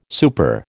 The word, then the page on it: super: American English